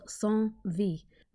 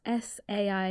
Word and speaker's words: Saint-Vit: So we think this episode was filmed in a place called Saint-Vit, which is spelled S-A-I-N-T-V-I-T, Saint-Vit.